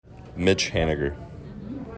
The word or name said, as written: Mitch Haniger